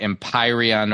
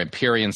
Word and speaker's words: Empyrean: So I would recommend, if you like fantasy fiction, check out the Empyrean or Empyrean series. It's spelled E-M-P-Y-R-E-A-N.